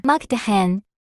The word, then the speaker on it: Mukdahan: Mukdahan.